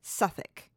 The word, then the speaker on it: Southwark: Oh, well, I just know that in English, like the word, it looks like on the page, Southwark with a W, W-A-R-K, but you pronounce it Suffolk.